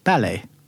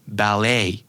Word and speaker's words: ballet: ballet ballet but the US might say ballet yeah probably with a more emphasis on the second stress right right yeah.